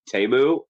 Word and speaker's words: Temu: He laundered his money through Temu. – Yeah. yeah. – Actually, it's pronounced Tamu.